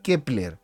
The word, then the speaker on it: Keplr: Que se escribe K-E-P-L-R, Kepler, ¿sí?